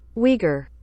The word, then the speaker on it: Uyghur: Uyghur.